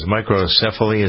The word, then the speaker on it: microcephaly: If you've got a baby, I think the first baby in Texas with Zika microcephaly, is that how you pronounce it? – Yeah, that's it.